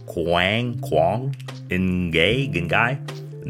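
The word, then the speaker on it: Quảng Ngãi: It's Quang Ngai.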